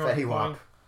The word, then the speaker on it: Fetty Wap: Fetty Wap. – Am I pronouncing that correctly? Yes, it is. You are.